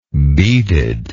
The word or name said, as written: beaded